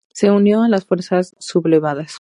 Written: sublevadas

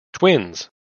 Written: Twins

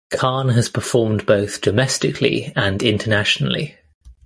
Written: internationally

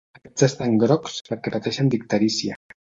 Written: pateixen

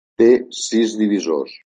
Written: divisors

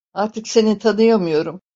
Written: seni

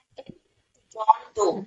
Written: Doe

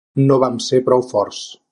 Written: vam

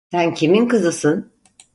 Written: kimin

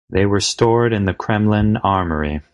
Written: stored

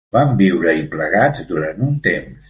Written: plegats

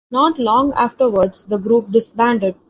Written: disbanded

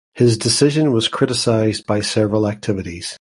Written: criticised

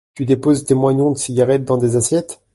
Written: moignons

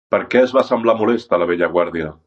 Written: molesta

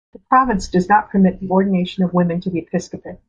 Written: episcopate

